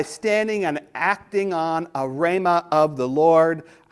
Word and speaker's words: rhema: The word of God generally is logos, L-O-G-O-S. But a specific word of God that directly challenges you is a R-H-E-M-A. Now, last week I pronounced this word as rhema. And a dear saint came up to me after the service and said, logos is logos, but R-H-E-M-A is, there's an A sound. It's rhema.